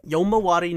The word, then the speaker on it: Yomawari: who's in the Womaro. How do you pronounce that game? – Yomawari Night Alone.